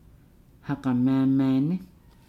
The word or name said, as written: hagamaamaane